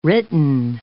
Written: written

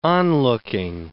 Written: onlooking

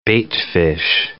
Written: baitfish

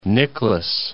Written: Nicklaus

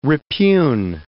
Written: repugn

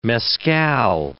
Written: mescal

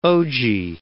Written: OG

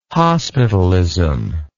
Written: hospitalism

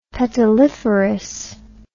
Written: petaliferous

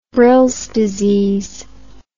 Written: Brill's disease

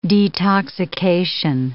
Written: detoxication

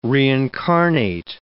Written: reincarnate

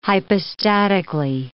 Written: hypostatically

